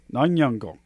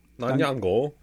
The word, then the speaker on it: Nyong'o: Lupita Nyong'o. Go ahead and pronounce that. Give that a try. – Lupita Nyong'o. – Nyong'o. – Nyong'o.